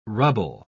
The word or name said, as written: rubble